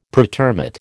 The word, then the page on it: pretermit: American English